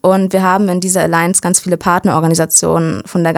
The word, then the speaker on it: Alliance: Und der NIG ist auch Mitglied in der Alliance of European Voluntary Service Organizations. Hoffentlich habe ich es jetzt richtig ausgesprochen. – Ja, die Alliance. – Alliance, oha, okay.